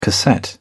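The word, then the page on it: cassette deck: British English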